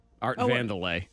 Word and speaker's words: Vandelay: Art Vandal J. – No. Art Vandelay. – Vandelay. – Art Vandelay. – Oh, yes, Vandelay, yeah.